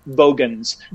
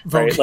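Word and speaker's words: Vogon: I feel like, especially his pronunciation of Bogans, Vogans.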